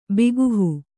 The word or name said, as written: ಬಿಗುಹು